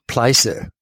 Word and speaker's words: Pleiße: But just remembering in Leipzig, we would row on the River Pleiße, which goes through the center of Leipzig, which rhymes with another word, German word, Scheiße, which listeners who speak German will know what that means.